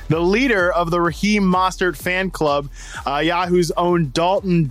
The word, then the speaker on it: Mostert: First off, as the number one fan, I must tell you to pronounce his name correctly. It's Mostert for one.